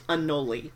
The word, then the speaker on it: anole: But before you get into chapter 16, I want to warn you, it is pronounced anole. If you pronounce it anole, then reptile nerds will make fun of you.